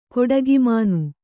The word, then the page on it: ಕೊಟ್ಟಿಗೆಮನೆ: koṭṭigemane